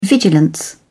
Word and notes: vigilance: British English

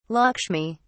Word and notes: lakshmi: British English

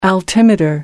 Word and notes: altimeter: American English

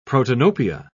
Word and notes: protanopia: British English

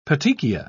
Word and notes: petechia: British English

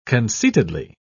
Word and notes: conceitedly: British English

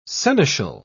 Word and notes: seneschal: British English

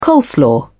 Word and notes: coleslaw: British English

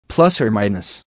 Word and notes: plus or minus: American English

